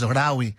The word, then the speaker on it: Lawi: He calls himself simply Rawi, L-A-W-I, Rawi, as in Malawi. But you cut the M-A. So Lawi, or pronounced, of course, Rawi.